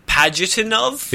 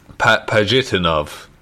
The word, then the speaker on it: Pajitnov: So, sharing them with its designer and creator, Alexei Pajitnov. – Yes. – I can't pronounce that name. – Pajitnov. – Yeah. Pajitnov.